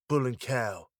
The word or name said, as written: bull and cow